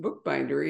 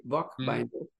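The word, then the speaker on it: Boekbinder: and I guess it's pronounced bookbinder, even though the spelling is South African, B-O-E-K, bookbinder.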